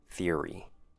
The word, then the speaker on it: theory: Next word, theory. 3 syllables. I know, it sounds like 2 syllables, but believe me, it's 3. If you break it down, you can kind of hear 3 syllables, but native speakers say it quickly, so it's hard to make out.